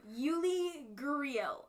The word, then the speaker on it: Yuli Gurriel: Number four, Yuli Gurriel.